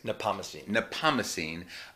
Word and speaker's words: Nepomucene: here at St. John Nepomucene. Did I say that correctly? – Nepomucene, in the east side of New York, mid-east side, 40s.